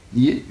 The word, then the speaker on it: nier: Mais je vous dis, alors, dans le cours du XVIe, XVIIe siècle, alors, prononciation actuelle qui s'installe, nier une seule syllabe.